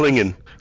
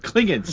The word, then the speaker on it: Klingon: It's Klingon. – Klingon, see, that is a correct pronunciation.